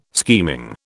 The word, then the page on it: scheming: British English